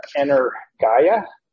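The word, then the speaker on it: energia (ἐνέργεια): Some might pronounce it energia, but in the Greek, you know, when we look at our pronunciation guide and you have the epsilon iota, it suggests we pronounce that as a long I, as a height. And so we're going to try it. Pronounce it that way, energia.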